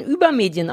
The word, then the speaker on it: Übermedien: Ähm, also, die richtige Aussprache ist eigentlich über Medien. – Mhm. – Ich sage aber reflexartig fast immer über Medien. Einfach, weil es natürlicher klingt, aber es ist, ja.